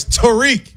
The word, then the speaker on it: Tarik: First of all, put some respect and say his name right. It's Tarik.